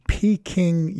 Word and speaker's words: Peking: Well, in particular, it was a speech that he gave to Peking University. That is the correct pronunciation in Beijing, which is also the correct pronunciation.